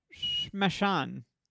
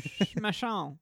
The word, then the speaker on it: Shmashan: No, my topic is Shmashan. – I think you got it spot on.